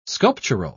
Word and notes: sculptural: British English

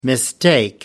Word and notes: mistake: American English

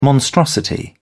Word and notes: monstrosity: British English

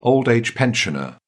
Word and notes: old-age pensioner: British English

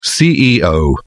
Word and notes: CEO: British English

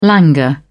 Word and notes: languor: British English

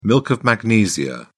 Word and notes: milk of magnesia: British English

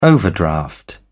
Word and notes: overdraft: British English

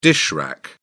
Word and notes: dish rack: British English

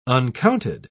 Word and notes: uncounted: British English

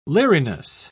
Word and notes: leeriness: British English

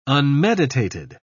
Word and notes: unmeditated: British English

